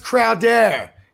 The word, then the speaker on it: Crowder: It's pronounced Crowder. It's French. Get it right.